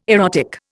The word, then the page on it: erotic: Indian